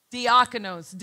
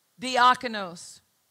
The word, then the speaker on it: diakonos: It's spelled D-I-A-K-A-N-O-S. Diakonos. D-I-A-K-A-N-O-S. Diakonos. Sounds like the word deacon.